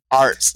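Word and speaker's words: Arzt: Ards. – It's A-R-Z-A-R-Z-T-Z. – A-R-A-T-Z. I think it's spelled A-R-D-Z. – It's A-R-Z-T.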